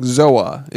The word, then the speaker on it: Zoa: So it's spelled Z-O-A and me saying Zoa is incorrect. – Wrong. – Okay. It's Zoa. – Okay, here. – You have to say it, you know, like a Samoan would.